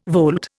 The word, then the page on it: volte: Indian